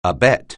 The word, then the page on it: abet: anglais